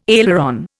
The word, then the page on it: aileron: Indian